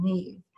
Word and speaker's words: Niamh: Neve. Her name is spelled N-I-A-M-H, but in Irish, it's pronounced Neve.